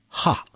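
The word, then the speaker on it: ha (ห้า): In the Thai language, the number five is pronounced ha.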